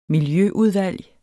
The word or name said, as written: miljøudvalg